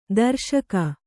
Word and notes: ತುರುಷ್ಕ: turuṣka

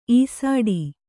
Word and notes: ಈಸಾಡಿ: īsāḍi

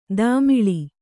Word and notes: ತಮಾಳ: tamāḷa

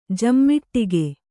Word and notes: ಜಮ್ಮಿಟ್ಟಿಗೆ: jammiṭṭige

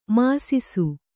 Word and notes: ಮಿಸಿಸು: misisu

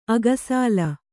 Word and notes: ಅಕ್ಕಸಾಲ: akkasāla

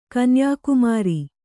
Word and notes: ಕನ್ಯಾಕುಮಾರಿ: kanyākumāri

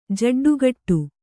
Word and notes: ಜೆಡ್ಡುಗಟ್ಟು: jeḍḍugaṭṭu

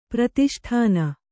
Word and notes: ಪ್ರತಿಷ್ಠಾನ: Kannada; pratiṣṭhāna

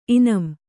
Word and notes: ಇನಾಂ: inām

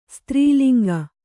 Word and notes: ಸ್ತ್ರೀಲಿಂಗ: strī linga

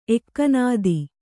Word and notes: ಎಕ್ಕನಾದಿ: ekkanādi

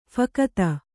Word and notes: ಫಕತ: phakata

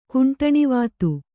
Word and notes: ಕುಂಟಣಿವಾತು: kannada

